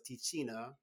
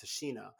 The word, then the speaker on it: Tichina: But I said the name wrong because I thought it was Tichina. I was saying Tichina Arnold because it was T-I-C-H-I-N-A. And I was like, this is like Tichina, but it's Tashina.